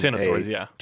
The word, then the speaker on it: X of Swords: It's Ten of Swords, and they fucking do that with the Roman numerals, and it's irritating.